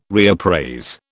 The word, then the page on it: reappraise: British English